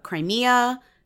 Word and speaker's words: Crimea: Crimea. Is that how you pronounce it? Yes. A territory that had been annexed.